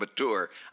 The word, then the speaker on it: mature: That's another one of those things, like champing at the bit, that once somebody said there's no CH in mature and that it was supposed to be pronounced mature, I couldn't not do it after that.